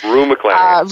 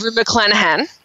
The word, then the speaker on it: Rue McClanahan: Rue McClanahan. – Rue McClanahan.